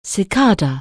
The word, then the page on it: cicada: anglais